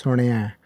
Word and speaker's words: Suniai: And the Sunaya itself is, when we say it quick, it's not always so accurate in the way we pronounce it. But the pronunciation, again, a reminder is it's Sunaya.